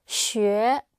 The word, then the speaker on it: 学: Blue finger up. Xue. – Xue. – Xue. – Sheng. – Sheng. – Xue. Sheng. – Xue.